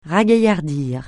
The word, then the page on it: ragaillardir: francais